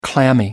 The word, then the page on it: clammy: American English